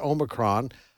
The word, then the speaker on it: Omicron: And that's the way she pronounces it, Omicron, out of South Africa.